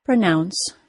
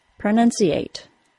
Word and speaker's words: pronounce: Like most two-syllable verbs, it is stressed on the second syllable, pronounce. The vowel sound of the stressed syllable is spelled OU and is pronounced as the OW sound, ow. The letters OU are usually pronounced as the OW sound, so there are no surprises here. Since the second syllable is stressed, the vowel sound of the first syllable is reduced to schwa.